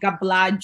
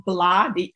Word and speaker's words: Bla: My name is Khadija Bla. The G is silent in my surname. People are always trying to say Gabla, Gibla. It's actually Bla. So it's like blah, blah, blah, blah, blah.